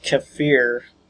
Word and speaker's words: kefir: Actually, it's pronounced kefir, K-E-F-I-R. I like to call it kefir, even though that's not correct.